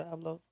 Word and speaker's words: Abloh: Virgil Abloh.